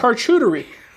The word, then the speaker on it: charcuterie: A charcuterie. A charcuterie board. – Charcuterie. – Yeah. I don't see why not, especially on a serving board. – How do you say that word? – Charcuterie.